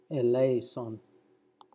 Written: Eleison

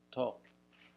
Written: tw

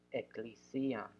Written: eklisiam

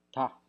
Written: ta

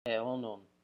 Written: eonon

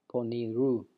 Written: ponirou

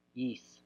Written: yis

